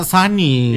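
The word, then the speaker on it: Sony: Iya, cinta pertama Suni kan. – Itu merek itu. Oh iya. Merek elektronik zaman dulu udah tutup. – Oh, enggak, memang gitu tulisannya, tapi bacanya Sani.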